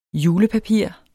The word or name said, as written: julepapir